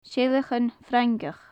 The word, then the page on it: seileachan frangach: gaelic